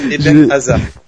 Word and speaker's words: Hazard: Je sais pas, je t'ai senti vouloir prononcer un peu Hazard comme ça. Oui, Eden Hazard. – C'est Dan qui m'a un peu motivé. Voilà, pour le prononcer à la juive. Non, je plaisante. Donc, parce que je me suis trompé, il me semble que j'ai prononcé un espèce de H avant le Eden.